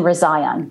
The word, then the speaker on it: Rezaian: Jason Rezaian.